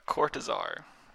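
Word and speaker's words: Cortázar: How do you say his last name? Assistance, please. You stop laughing. – Hopscotch by Julio Cortazar. – Yeah, Cortazar. That's it.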